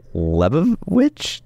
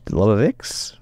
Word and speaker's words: Lebovics: Zach Lebovich? Lebovix? Vich. Vich. There we go.